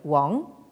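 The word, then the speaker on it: Wang (王): C'est un caractère qui se prononce, comment dire, dans la prononciation moderne Wang.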